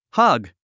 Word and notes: hug: hʌ́ɡ; ハグ

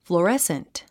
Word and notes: fluorescent: flu ə résnt; フルアレスント

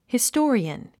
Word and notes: historian: histɔ́ːriən; ヒストーリアン

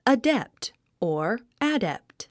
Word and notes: adept: ədépt; アデェプト

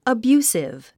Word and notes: abusive: əbjúːsiv; アビュースィブ

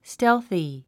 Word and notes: stealthy: stélθi; ステルフィ

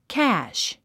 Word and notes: cash: kǽʃ; キャァッシュ

